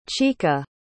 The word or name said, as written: chika